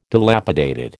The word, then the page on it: dilapidated: American English